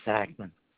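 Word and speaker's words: Sackmann: Klein Sackman.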